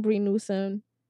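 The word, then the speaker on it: Bree Newsome: I like to use the example of, remember when Brie Newsome, that's her name, I'm saying it correctly. – Yes.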